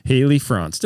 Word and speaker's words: Franc: We are welcoming our guest, Haley Franc. Did I say that correctly? – Yeah, Franc.